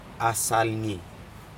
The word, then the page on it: asalgii: Aneityum